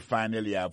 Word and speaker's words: finally: Alors, t'aimes plus finally, pour nous, finally, c'est F-I-N-A-L-L-Y, finally. T'as des prononciations, oui? Finally. Accent tombé sur le premier syllabe là. Finally. F-I-N-A-L-L-Y.